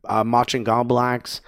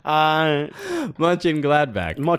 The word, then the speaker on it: Mönchengladbach: That was the worst pronunciation of that. – Yeah. Well, people know what I'm talking about. It's the sauce. The Worcester sauce. Worcestershire sauce. Anyway. – Mönchengladbach.